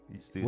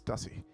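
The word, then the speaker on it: Stüssy: Oh, it's pronounced Stussy? – Stussy. – What? I thought it was Stussy. – I used to say Stussy, too, until people like Miles sent me up to Stussy.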